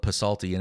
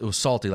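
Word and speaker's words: Psalty: But I didn't know that the P was silent as a kid, so I always called it Pasalty. And everybody laughed because they thought I was really clever. Apparently I just couldn't read. But it was salty, like Psalms, like with the silent P.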